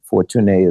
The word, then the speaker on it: Fortuné: So for our friends here listening, Fortuné de Saint-Germain, Fortuné is my name in French. That's how you really pronounce it, Fortune in English.